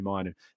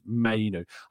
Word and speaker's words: Mainoo: Mainoo? It's just simply not happened. And Austin's correct, my pronunciation is Mainoo.